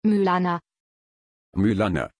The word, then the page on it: Mylana: German